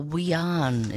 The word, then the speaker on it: Wiyan: That's Kyle Slab with a little bit about the story behind the Wiyan. Is that how you pronounce it? – Yes, exactly.